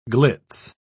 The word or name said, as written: glitz